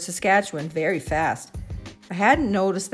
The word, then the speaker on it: Saskatchewan: I listened intently, leaning in, waiting patiently for them to finish sounding out all four syllables Saskatchewan.